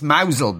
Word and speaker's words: Mousehole: It's pronounced Mousel, but it's spelt Mousehole.